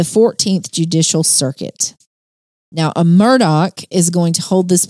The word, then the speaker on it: Murdaugh: Well, first of all, if you notice, it's spelled M-U-R-D-A-U-G-H. And you're like, which is confusing. – You're saying that wrong, but it is pronounced Murdoch, despite the spelling.